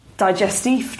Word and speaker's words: digestif: And then the lovely member of staff rolled round this sort of digestif trolley.